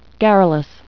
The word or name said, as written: garrulous